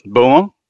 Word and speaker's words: Beaumont: What, Beaumont? – Yes.